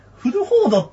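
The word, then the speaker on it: 古歩道: でも、僕ね、最近思ったんですけど、ベンジャミンさんの名前の前にいつも、古いって漢字で書いて、歩くって書いて、道って書いて、僕、国道って読んでたんですよ。あれ、フルホードっていう読み方だったんですね。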